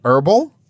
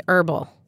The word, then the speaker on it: herbal: you mean herbal? – Yes. In the United States, we say herbal. But in Britain, they would say herbal.